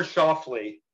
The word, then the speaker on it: Schauffele: Xander Scafuffle. – Xander Schauffele. Yes, I, I always butcher this poor guy's name.